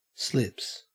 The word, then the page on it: slips: Australian English